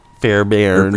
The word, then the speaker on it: Fairbairn: I went Fairburn or whatever. It's spelled like B A at the end. It's B A I R N. So it's like Bairn.